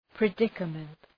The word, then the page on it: predicament: prı’dıkəmənt